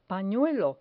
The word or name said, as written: panuelo